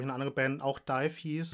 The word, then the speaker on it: DIIV: Den kennt man vielleicht als Frottmann der Band Dive. Also D-I-I-V. – So werden die ausgesprochen. – Dive, ja.